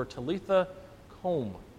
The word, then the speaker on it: Talitha kum: He took her by the hand and said to her, Talitha kum, as I found out what the pronunciation was, which means, little girl, I say to you, get up.